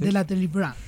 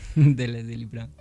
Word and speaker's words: de la Délivrance: La pronunciación del francés. – De la Délivrance. – De la Délivrance.